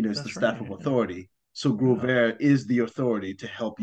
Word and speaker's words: Growvare: It's Growvare.